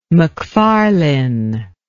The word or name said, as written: macfarlane